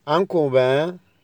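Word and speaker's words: un copain: انكو با يعني زميل، انكو با الان الاخيره تنطق خفيفه كده من الانف،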